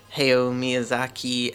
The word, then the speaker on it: Hayao Miyazaki: It's a Japanese animation studio, which has produced many classics over the years, and some famous directors like Hayao Miyazaki and Isao Takahata.